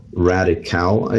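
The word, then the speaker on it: Radicale: It's called Radical, I guess is how you would pronounce it. It's like radical with an E at the end.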